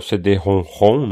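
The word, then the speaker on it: CD-ROM²: O PC Engine, no finalzinho de, de 88, chegou inclusive com o primeiro jogo do PC Engine CD, que é o CD-ROM-ROM, né? Aquele CD-ROM ao quadrado se escreve, mas se lê ROM-ROM.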